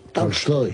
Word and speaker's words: Tolstoy: No es exactamente Tolstoy, es Tolstoy. Tolstoy. – ¿Cómo? Lo dije. – Es Tolstoy con una. – Embajador. Tolstoy. Lo dije yo así tal cual. Tolstoy.